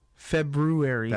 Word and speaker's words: February: I was talking to somebody the other day who didn't know that there was an R in February. – You're still pronouncing. Why would you? You don't have to pronounce it that way, though, you know. – Because there's an R.